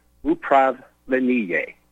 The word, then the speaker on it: upravlenie: And the operative word here today is the Russian word upravlenie.